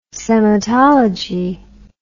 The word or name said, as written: sematology